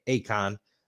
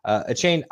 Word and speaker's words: Achane: You're going to start Mostert and Akon. Both of those two can play at any time. His name's not actually pronounced Akon, but that's how I'm pronouncing it. Achane,